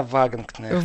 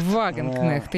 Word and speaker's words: Вагенкнехт: Итак, э, Сара Вагинг-Нехт. Сара Вагенкнехт. Вагенкнехт. Я вот тоже начинаю правильно, наконец, произносить немецкие фамилии.